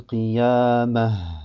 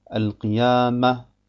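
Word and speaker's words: qiyamah (القيامة): Man sagt also, La uqsimu bi yawmil qiyamah, wa la uqsimu bin nafsil lawwamah. Man soll auf keinen Fall sagen, Al-Qiyamah, Al-Lawwamah, Idhama.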